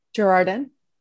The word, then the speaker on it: Girardin: We brought in a dear friend of mine who, Rachelle Girardin, do I even say your name right? One of my oldest friends. – Very close. – Okay. How do you say it? – Girardin.